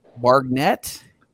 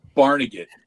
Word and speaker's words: Barnegat: And that's going to be in, is it pronounced Bargnet? – Bargnet. But now you got me saying it wrong. Barnegat.